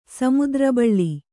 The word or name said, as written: ಸಮುದ್ರಬಾಳೆ